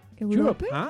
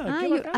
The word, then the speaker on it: Europe: ¿Europa? Europe. – Así se pronuncia.